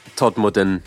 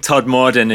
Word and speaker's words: Todmorden: I massacred the pronunciation of Todmorden. I went Todmorden earlier. Not good. – Well, I think Todmorden is more of the kind of...